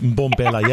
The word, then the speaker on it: Mbombela: We're starting off in Johannesburg and we're heading to Mbombela. Am I pronouncing that right? I better be. – Yes. Well done. Yes. Yeah. Nice, but Mbombela.